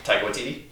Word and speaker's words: Taika Waititi: Taika Waititi? – Yeah, Taika Waititi.